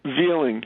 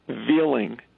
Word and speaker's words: Veiling: His name is Larry Veeling. He's a firefighter. We never found him. His last name is spelled V-E-I-L-I-N-G, Veeling, and they've never pronounced it right.